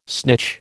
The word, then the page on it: snitch: American English